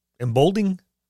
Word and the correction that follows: Emboldening: Am I saying that right? Embolding body or bond. – Embolding bond. – Embolding bond. – Emboldening. – Emboldening. Yeah, that's what I thought. I thought it was another syllabic in there, syllable in there.